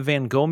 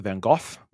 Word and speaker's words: Van Gogh: We went to the Van Gogh Museum, or as we learned on the audio tour, the Van Gogh Museum. It turns out, been saying it wrong the entire time.